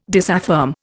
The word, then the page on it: disaffirm: Indian